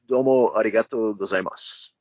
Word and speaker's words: domo arigato gozaimasu: And well, the only thing I can say to you, I can say it in Japanese, pardon if I butcher the language, but domo arigato gozaimasu. – Oh, you said it perfectly.